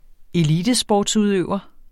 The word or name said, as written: elitesportsudøver